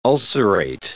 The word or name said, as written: ulcerate